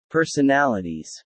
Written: personalities

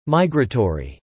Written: migratory

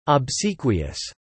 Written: obsequious

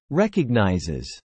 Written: recognizes

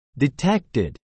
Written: detected

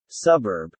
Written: suburb

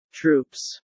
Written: troops

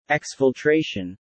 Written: exfiltration

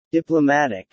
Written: Diplomatic

